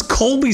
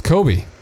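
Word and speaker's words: Cobie: I thought it was Colby Smulders. – No, it's Kobe. – It's a Kobe, like Kobe Bryant?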